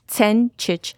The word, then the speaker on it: Sencic: Sencic. So most people here say Sencic because they see the C-E-N and they kind of just say Sen. But it's actually kind of like a T-S together, like Sencic.